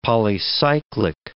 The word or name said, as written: polycyclic